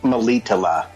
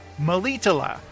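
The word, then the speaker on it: Melitele: He is uh, recuperating at this uh, this uh, temple of uh, Maletali. How did you say it? – Malitala. – Malitala with Neneke, who is nursing him back to health.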